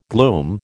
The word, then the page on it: gloom: American English